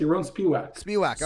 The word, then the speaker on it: Yaron Spiwak: Yaron Spiwak, is that how we say it? – Pretty close to your own Spiwak.